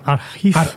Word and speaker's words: Archiv: En, alemán, en alemán sería Archiv. – archiv. Archiv, pero no creo que sea alemán eso. No. – No, bueno, no lo he escuchado nunca como Archiv.